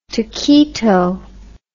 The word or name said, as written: taquito